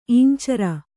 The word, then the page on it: ಈಂಚರ: īñcara